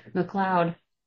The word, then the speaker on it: McLeod: McLeod.